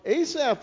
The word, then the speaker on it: Asaph: This one was written by a man named Asaph, A-S-A-P-H. A-P-H pronounces like an F. So Asaph, and Asaph was a worship leader.